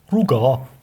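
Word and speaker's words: Rugaard: Ähm, erstmal würde man das nicht so schreiben, aber dann wäre es eher so also das D würde man nicht aussprechen. Rugar. Rugar. Oder Rugar. Ja, Rugar. Also Gor ist also G-A-R-D, beziehungsweise A mit so einem Kreis drüber, ist der so Hof oder so ein abgestecktes Ach so.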